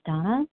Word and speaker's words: Dana: This is Donna B. I spell my name D-A-N-A, but it is pronounced Donna.